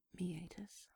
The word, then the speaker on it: meatus: It's pronounced meatus, but it's spelled M-E-A-T-U-S. meet us. I'm not kidding. Yep, the hole in your dick is called meet us.